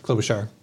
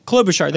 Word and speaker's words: Klobuchar: Klobuchar Warner. I may be mispronouncing her name, but it's the Honest Ads Act. Klobuchar. – Klobuchar. There you go.